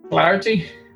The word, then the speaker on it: clarty: Clarty or Clarty? – Clarty? No idea. – It's Clarty.